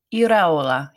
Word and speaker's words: Iraola: Iraola, yeah.